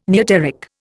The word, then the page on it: neoteric: Indian